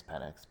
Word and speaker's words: Penix: Plus, people want to see if I pronounce it Michael Penix or Michael Penix. Right. It's Penix, but I, I know.